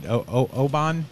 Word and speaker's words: Oban: I'm drinking, how do you pronounce it? Is it Oban? – Yes.